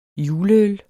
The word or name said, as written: juleøl